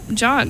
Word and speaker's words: John: How do you pronounce John? – The Q is silent in John, so it's just John.